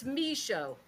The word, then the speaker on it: Michaud: So can you pronounce your last name again for us? – Yeah, it's Micho.